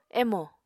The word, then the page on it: emo: Apatani